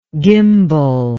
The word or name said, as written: gimbel